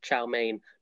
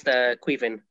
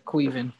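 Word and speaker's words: Caoimhín: When I saw his name, I thought it was pronounced Chow Mein, not realizing he was Irish. So apologies to all our Irish listeners. – Yeah, his name is pronounced Queevin. – Yeah, Queevin, and so like that has transpired to be true so far.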